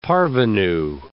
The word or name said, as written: parvenue